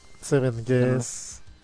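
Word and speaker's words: 7th Guest: Seven Guests. No. Y ahí lo pronuncié mal como lo escribió él. Seven Guests. Y es Seven Guest.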